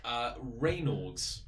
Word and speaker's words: Raynaud's: Let's try a different pronunciation. – Oh, do. – Renard. Renaud? – Renault? – Renaud. – The car. – You probably should have looked this up. – I'm reading it. Raynaud's. Raynaud.